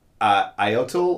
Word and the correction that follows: Ayton: or DeAndre Ayton? – Ayton. – DeAndre Ayton, sure.